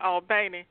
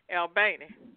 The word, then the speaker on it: Albany: We're also sending cards to the hospital in Albany, and they pronounce it Albany, Georgia. But you know, people say Albany, but that's Albany, New York. But in Georgia, they call it Albany.